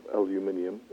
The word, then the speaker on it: aluminium: We have a research scientist with a huge amount of knowledge on the body burden of, excuse my pronunciation, of aluminium.